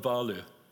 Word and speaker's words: Wahlöö: Sjöwall and Wahlöö.